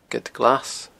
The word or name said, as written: geadglas